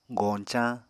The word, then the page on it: goñcañ: Apatani